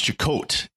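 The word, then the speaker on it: Chekote: Well, later on, it is pronounced Chakote instead of Chakotay.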